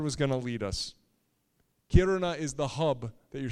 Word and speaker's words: Kiruna: Can you say Kiruna with me? Roll your R if you can. Kiruna.